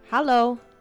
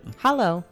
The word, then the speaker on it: Hallo: How do you say hi in German? – Hallo. – That's it? – Hallo. H-A-L-L-O.